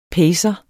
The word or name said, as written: pacer